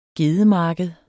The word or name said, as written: gedemarked